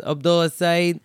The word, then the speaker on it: Abdullah Saeed: I was at one time in my life, but now I pronounce it like the way I say it is Abdullah Saeed.